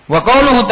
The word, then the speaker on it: wa qauluhu: Wa qauluhu ta'ala, bacanya wa qauluhu karena ataf ke qauluka.